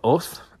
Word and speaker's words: Howth: So the Summit Inn, it's sort of at the tip top of Oth, or Howth. All right? It's spelled H-O-W-T-H.